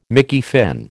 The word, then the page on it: Mickey Finn: American English